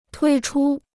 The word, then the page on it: 推出: tuī chū